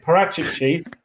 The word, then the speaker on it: Paratici: Fabio Paratici, you've got me saying his name wrong again. Paratici, Fabio Paratici's Tottenham is going to look like.